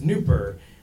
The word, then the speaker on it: Nupur: My, my given name is Nupur, which several elementary school bullies had previously recognized also rhymes with pooper and scooper.